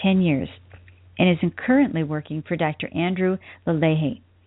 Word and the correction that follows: Lalier: And I think I probably still said that wrong. – It's Lalier.